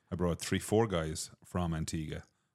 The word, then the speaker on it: Antigua: But then when you said Antigua, or Antigua, how do you pronounce it? – It's Antigua.